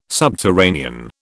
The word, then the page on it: subterranean: British English